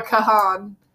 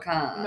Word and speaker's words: Kahan: We always want to say Noah Kahan, because when we were first getting into him, that's how we said it to each other, until we realized it's actually not really pronounced like that, so now we have to think about it every single time we say his name.